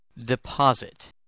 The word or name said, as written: deposit